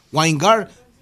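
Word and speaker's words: Winegard: Weingard. Se escribe Wine, así que de vino todo junto, y Gard, G-A-R-D. Weingard, Timothy C.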